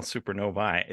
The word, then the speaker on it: supernovae: I knew I was pronouncing it incorrectly when I saw that up, E. Like, is it, I saw that supernova? E at the end and I'm like, what am I supposed to say?